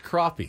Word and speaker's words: crappie: It's crappie. crappy. well, that's what they, they call it. They said it's pronounced crappy. – crappie. The down south they call it crappie.